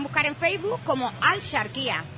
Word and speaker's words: Axarquía: Intentamos pronunciarlo de la manera antigua al charquía, que era como se decía antes.